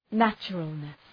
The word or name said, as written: naturalness